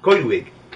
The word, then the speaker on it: Coedwig: Coedwig. But the thing is, the W is pronounced ooh. – Mm-hmm. – Coedwig.